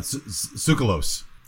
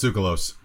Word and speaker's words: Tsoukalos: No, Tsoukalos. He's Greek. – That's how you say that name? – Yeah, it's Tsoukalos. – Are you kidding? – I promise. – Okay. – Tasso Tsoukalos.